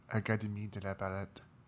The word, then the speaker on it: Académie de la Palette: When she arrived in Paris, she enrolled at the Académie de la Palette. I'm guessing that's not how you pronounce it. – Académie de la Palette. – That's what I said.